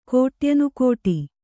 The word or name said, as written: ಕೋಟ್ಯನುಕೋಟಿ